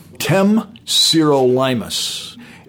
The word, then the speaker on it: Temsirolimus: Temsirolimus.